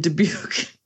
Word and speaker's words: Dubuque: Right Dubuque. In the center of Dubuque, 67 Main Street. I mean, you don't get much more center than 67 Main Street. – I rebuke you in Dubuque.